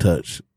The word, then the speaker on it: Techt: So if you see it, it's spelled T-E-C-H-T, but it's pronounced Touch. It's the Latin version.